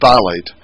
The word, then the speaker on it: phthalate: It's spelled with a P. The P is silent, phthalate.